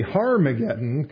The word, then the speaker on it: Harmageddon: But the fact of the matter is that Armageddon, or even more correctly, Harmageddon, kind of like our, at least the NASB mentions there in verse 16, is the proper pronunciation. It has an H on the front of it there, is a real place that is in the nation of Israel.